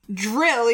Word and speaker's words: Dryl: Dryl, even though it's not spelled, it's spelled D-R-Y-L. It's a place where people drill.